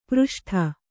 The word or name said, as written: ಪ್ರಷ್ಠ